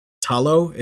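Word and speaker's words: Ta Lo: So it's more about a land, and Talo is how you say it, is where she's from.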